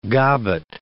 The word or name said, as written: gobbet